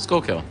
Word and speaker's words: Schuylkill: So you have Luzerne, which is where Hazelton is, Carbon County, Lackawanna County, Northumberland County, and Schuylkill County, which I think I'm pronouncing right. – Schuylkill.